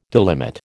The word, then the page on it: delimit: American English